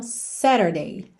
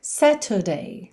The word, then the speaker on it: Saturday: Aí aqui, como a gente tem um tzinho no meio da palavra, se você optar por uma pronúncia mais americana, você vai fazer o rarara nesse T e vai ter então Saturday, Saturday. Se você optar por uma pronúncia mais britânica, Saturday, Saturday.